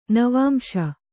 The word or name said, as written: ನವಾಂಶ